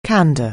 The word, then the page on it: candour: anglais